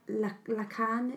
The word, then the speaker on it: Lacan: Yeah, it's quite like Lacan. Is that how you say his name? – Yeah.